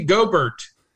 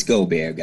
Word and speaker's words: Gobert: Rudy Gobert. Hard G Gobert. – It's Gobert, guys.